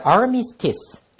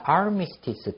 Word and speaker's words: armistice: 자, armistice. 자, 휴전, 정전이란 뜻입니다. armistice 또는 armistice 두 가지 발음 다 됩니다.